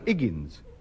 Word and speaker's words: Higgins: Higgins. – No, Higgins. The H is silent as in oats.